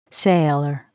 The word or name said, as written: sailor